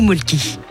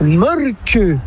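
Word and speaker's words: Mölkky: Ça s'écrit molki, mais ça se prononce molke. – Le molke. – Plutôt un E qu'un E, mais pas un U. Donc ça fait molke.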